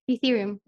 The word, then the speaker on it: Ethereum: Em, eh, cuéntanos de Ethereum, eh, así se pronuncia. Sí, Ethereum.